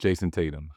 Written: Jayson Tatum

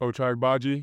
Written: Ochai Agbaji

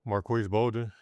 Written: Marques Bolden

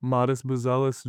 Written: Matas Buzelis

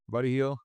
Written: Buddy Hield

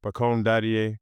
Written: pacome DADIET

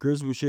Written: Chris Boucher

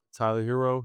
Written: Tyler Herro

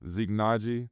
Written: Zeke Nnaji

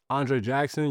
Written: Andre Jackson Jr

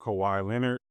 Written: Kawhi Leonard

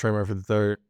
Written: Trey Murphy III

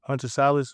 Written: Hunter Sallis

